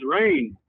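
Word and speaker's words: Rayne: My name is Rain. – Rain? – Yeah, like the weather, but it's spelled like Wayne, but with an R instead of a W.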